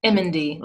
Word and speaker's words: Emendy: Emendy, like you're saying the Emendy.